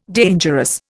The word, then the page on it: dangerous: Indian